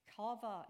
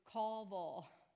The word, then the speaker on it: kava: This particular word for wait is the word kava. It's pronounced in the Hebrew kava. Say that with me, kava.